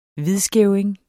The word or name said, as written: hvidskæving